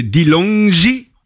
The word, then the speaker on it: Dilongi: Costaud, comme ton nouvel album qui est sorti il y a une semaine maintenant, c'est Dilongi. J'espère que je prononce bien. – Oui, très bien.